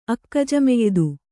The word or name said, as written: ಅಕ್ಕಜಮೆಯ್ದು